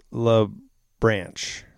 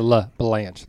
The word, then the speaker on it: LaBranche: We know Jenny, but it's LaBranche. – LaBranche. – LaBranche, right? Am I saying that right? It's got an E. It's branch with an E and an LA in the beginning, if that makes sense.